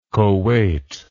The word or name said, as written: koweit